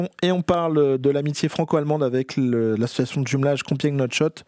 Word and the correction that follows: Landshut: Comment on prononce exactement? – Landshut. – Landshut, pardon. Landshut.